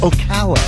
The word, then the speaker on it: Ocala: I think for the joke to work, you have to know it's pronounced Ocala.